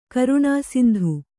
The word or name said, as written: ಕರುಣಾಸಿಂಧು